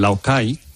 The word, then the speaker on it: Laogai: El Laocai, que así se pronuncia en chino. – ¿Cómo? – Laocai. La se pronuncia como K, como una K.